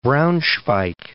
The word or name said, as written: Braunschweig